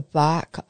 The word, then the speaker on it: barque: Some of today's symbols are that of the church as a boat, known as the bark of Peter. And if you don't know what a bark is, it's spelt B-A-R-Q-U-E.